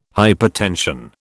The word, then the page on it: hypertension: British English